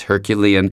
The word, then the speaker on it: Herculean: I realized I had trouble pronouncing the word Herculean because I pronounced it Herculean, and Jessica Kay said, Hold on one second, let's check the pronunciation. And she was right. Just like you would say Hercules, it's Herculean.